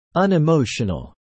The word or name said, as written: unemotional